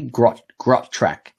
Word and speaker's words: Grotrac: Grot track. – It's like truck. It's like got track.